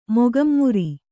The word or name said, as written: ಮೊಗಂಮುರಿ